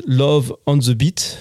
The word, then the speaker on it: Love on the Beat: was auf Französisch Love on the Beat ausgesprochen wird.